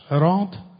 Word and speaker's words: صراط: کلمه صراط به لحاظ لفظی که برامون روشن است، به لحاظ لفظی این واژه را هم صراط میخوانیم، هم میتوانیم صراط بخوانیم، یعنی با سین بخونیم.